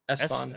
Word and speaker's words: Esfand: Isfand? Isfond? – Esfond. Esfond? Esfond? Esfond? – Anyways, yes. E-S-F-A-N-D.